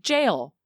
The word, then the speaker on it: gaol: I think maybe someone just didn't know how to spell, because I was like, gaol, and when I looked up the pronunciation, it just said jail. Like, do people spell jail like that in Canada? – Did you say J-A-O-L or G? – G-A-O-L.